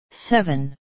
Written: seven

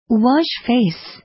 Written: wash face

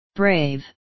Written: brave

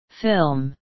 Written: film

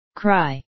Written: cry

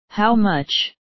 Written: how much

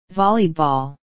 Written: volleyball

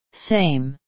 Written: same

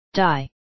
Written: die

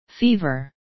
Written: fever

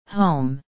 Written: home

